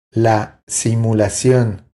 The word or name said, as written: La simulacion